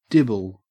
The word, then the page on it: dibble: Australian English